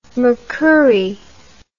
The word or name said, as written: Mercouri